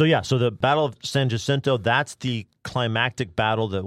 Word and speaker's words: San Jacinto: San Jacinto. You non-Texans don't dare call it the Battle of San Jacinto.